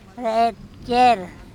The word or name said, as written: rEt cEr